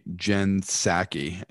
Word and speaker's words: Psaki: Now, White House Press Secretary Jen Psaki. I think that's how you pronounce her name, even though it's a P at the beginning. – The P uh, is you know, silent.